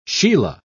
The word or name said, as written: sheila